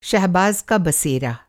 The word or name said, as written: बसेरा